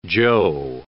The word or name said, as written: Joe